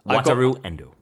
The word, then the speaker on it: Wataru Endo: Wataru Endo.